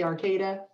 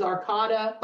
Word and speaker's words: Arcata: I didn't even know how to say Arcata. I thought it was Arcata.